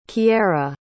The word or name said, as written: kiara